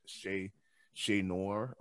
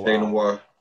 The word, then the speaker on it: Schenor: Her name is Shay Noir. Like, you spell it S-C-H-E-N-O-R. – Shay Noir.